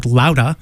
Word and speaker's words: Loudah: Really excited that Sal Baglio, our friend from the Stompers, is back with a great record called Louda. L-O-U-D-A-H, supposed to be pronounced like that in Bostonese.